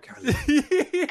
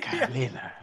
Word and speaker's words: Carolina: You got to call her like, you got to say it like it's a wind, like a wind chime. Carolina. – Carolina.